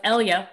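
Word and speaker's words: Eyla: You're listening to Eat It Virginia with Roby and Scott and Danny Sterling of Elia, which essentially rhymes with Helia.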